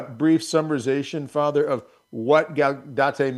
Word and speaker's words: Gaudete: This Sunday is the world famous, am I going to pronounce this right? Gaudete? – Right. I do Gaudete. – Gaudete. Okay.